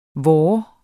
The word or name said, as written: vor